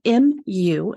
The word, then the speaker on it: Mu: So it's spelled M-U and pronounced Mu, like a kitten.